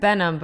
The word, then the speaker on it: Behnam: Did I say your last name correct? – Behnam, but I like the way you say it.